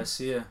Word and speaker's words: Sia: Sia? Yeah, Sia.